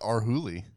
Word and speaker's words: Arhoolie: I think that's how it's pronounced. – Arhuli? – A-R-H-O-O-L-I-E. – That seems like Arhuli.